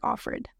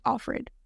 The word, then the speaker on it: Offred: Like, they have to, but if they'll like go like by of that, and then it's just named like Offred. Like, I think in the show they pronounce it like Offred, but it's of Fred.